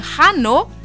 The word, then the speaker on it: hanno: und Hanno, sie haben, zum Beispiel. – Hm? Mhm. Aber das wird nicht ausgesprochen.